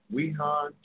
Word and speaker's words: Wuhan: Weihan, is it? Wuhan, China, where it originally emerged from.